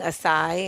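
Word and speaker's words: açaí: I just had an acai bowl.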